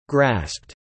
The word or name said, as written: grasped